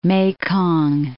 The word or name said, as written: Mekong